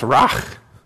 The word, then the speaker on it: ruach: Now, in Hebrew, the word spirit is pronounced ruach.